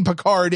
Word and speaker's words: Picard: Because he's French. And that's how you would pronounce the word name Picard in French, is Picard.